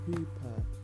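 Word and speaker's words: chuppah: The marriage ceremony takes place under a canopy, the kippah, pronounced hooper. Okay, so the hooper.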